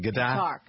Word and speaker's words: Qatar: The country of Qatar, is that how you pronounce it? – Qatar. – Right. – The story. Qatar, but with a Q.